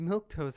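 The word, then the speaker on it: milquetoast: But it's pronounced milk toast.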